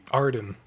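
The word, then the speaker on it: Ardyn: I think it is Aiden. – Yeah. – But I think it's spelled weird. Let's say A-E-D. – Arden. – Arden. That's what it is, yeah.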